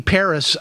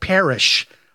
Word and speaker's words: Paris: You know, and people think it just rolls off your tongue, you know, some names don't, but my name being Paris, I'm called Parrish a lot. – Okay. – And I always tell people that the H is silent and it's also invisible, but you can call me Parrish if you want.